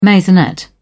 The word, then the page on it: maisonette: British English